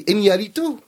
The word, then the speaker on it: Iñárritu: Iñárritu.